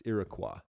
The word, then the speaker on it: Iroquois: I mean in plural, because you don't say the S, Iroquois.